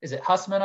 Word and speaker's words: Hussman: The person whose name is at the center of the journalism school, Walter Hussman, I believe you pronounce it? – Yeah.